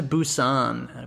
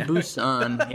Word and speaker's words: Busan: Called Train to Busan. I believe I'm pronouncing that right. – Yep. Yeah. – Train to Busan.